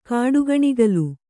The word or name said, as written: ಕಾಡುಗಣಿಗಲು